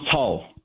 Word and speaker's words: Cao: Is it Pastor Cao? – Yeah, Pastor John Cao. – Thank you. Can you tell me his background? What is his story? – Thank you, for having me once again. What happened to Pastor John Cao, his last name is spelled as C-A-O, C for Charlie, C-A-O.